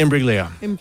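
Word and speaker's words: Imbruglia: Soft G. – Imbriglia. – Bang. – Imbriglia. Natalie Imbriglia.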